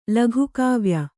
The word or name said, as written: ಲಘುಕಾವ್ಯ